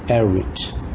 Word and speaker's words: Erwitt: O Elliot Erwitt, ele é de origem inglesa, então se pronuncia Erwitt.